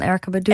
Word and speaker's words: Erykah Badu: Ja, van Erykah Badu.